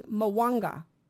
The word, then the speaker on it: Mwanga: Okay, and Magwanda or something, his name, I don't know how to say it, it's Mwanga, King Mwanga of Uganda.